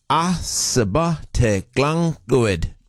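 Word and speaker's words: Ysbyty Glan Clwyd: That's how it's said, according to and the Welsh pronunciation subreddit says that would be pronounced Ysbyty Glenglwyd.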